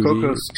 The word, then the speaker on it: Cocos: How do you say it? Cocos 2D? – Cocos 2D, yeah. Because yeah. Cocos is actually a city, apparently. It's just it's completely coincidence that it sounds kind of like Coco. – Right. – It's spelled C-O-C-O-S.